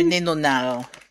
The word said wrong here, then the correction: nonénal: Trois syllabes. Nonénal. – Nonénal. – Sauf que ça s'écrit N-O-N-2-énal. Mais ils le mettent en ensemble. Nonénal. N-O-N-E-N-A-L. Ils retirent le 2 au milieu. Mais c'est nonénal.